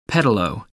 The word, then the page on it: pedalo: American English